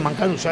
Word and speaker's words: Mancaruso: como es la de Philly Mancarus's Fortress. No. Sí, Mancaruso, ahí lo he dicho bien.